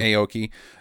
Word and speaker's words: Aoki: How do you say her name? – It's either Devon or Devin Aoki. – Aoki.